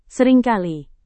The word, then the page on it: seringkali: Indonesian